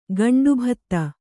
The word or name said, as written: ಗಂಡುಭತ್ತ